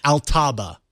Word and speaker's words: Altaba: Also, as Verizon will be purchasing Yahoo's core business, the remaining investment company that won't be going to Verizon will be renamed I almost said it wrong. It's like instinctive to say it wrong. Altaba, which kind of sounds like it could be Altbaba because in a former life it was Alibaba.